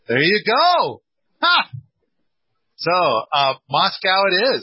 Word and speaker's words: Moscow: And I kind of, and then I remember there, they're like, no, if you're talking about the one in Russia, that's Moscow. – But this, it's spelled the same, okay. But it's Moscow here in Idaho.